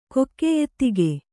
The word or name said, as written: ಕೊಕ್ಕೆಯೆತ್ತಿಗೆ